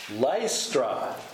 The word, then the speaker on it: Lystra: Lystra. – Lystra, correct. – Lystra. – It sounds kind of Dutch, doesn't it? Lystra.